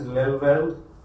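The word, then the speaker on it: Lelyveld: Next comes David, I suppose the pronunciation is Lelveld, David Lelveld.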